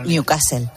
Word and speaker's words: Newcastle: Bueno, no, no lo has pronunciado bien. Se diría Newcastle.